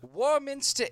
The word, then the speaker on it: Warminster: Warminster, England is a town of about 17,000 in the county of Wiltshire in southwestern England. – Hey, you said it right, I think. Good for you.